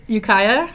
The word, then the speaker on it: Ukiah: Let's just let everyone know where, how do you pronounce it? – Ukiah? – Ukiah is.